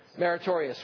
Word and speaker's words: meritorious: It's materius, however you pronounce it, merit-based. Pronounce it for me. Meritorious, right. I got it right in the 8:30 service.